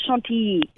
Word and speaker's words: Chantilly: She is in Chantilly. Is that how you pronounce it, Maria? Chantilly, France? – It's more like Chantilly.